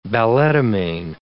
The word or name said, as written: balletomane